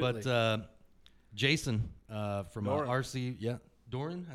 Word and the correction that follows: Duran: is that how you say it? See, I'm awful with bad names. I'm Duran. – I'm sorry, yes, Duran.